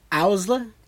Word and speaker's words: Owsla: And then underneath the lead rabbit is a military police who are called the Osla? Did I say that right? – Owsla? – The Owsla, yeah. – I don't know if it's a hard O or a soft O.